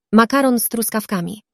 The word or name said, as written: makaron z truskawkami